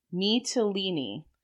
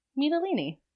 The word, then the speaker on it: Mytilene: He won the Civic Crown for his part in the siege of Mytilene. – Mytilene.